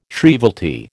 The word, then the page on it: shrievalty: American English